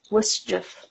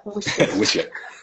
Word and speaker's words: WSJF: Yeah, it's still Wischief. Is how you pronounce that? – Wischief.